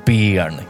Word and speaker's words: Béarn: Det uttalas Béarn, Kalle. Béarn.